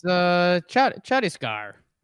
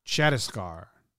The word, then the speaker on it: Chhattisgarh: Can you pronounce the name of that central state right there? – It's Chhattisgarh. – Chhattisgarh. – Double H is that's tough.